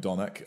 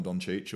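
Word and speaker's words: Dončić: Around to not calling him Donic. I'm going to call him Doncic or whatever. – Doncic.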